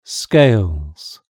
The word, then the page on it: scales: British English